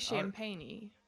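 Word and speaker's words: Champagnie: How you pronounce his last name? – I say Champagne.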